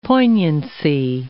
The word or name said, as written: poignancy